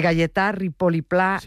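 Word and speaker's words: Gaietà Ripoll i Pla: a ver si lo digo bien, de Galletà Ripoll i Pla, un mestre lliure pensador que tenía la seua escola a l'Horta de Rusafa. – Perfecto. – ¿Lo he dicho bien? – Sí, vale.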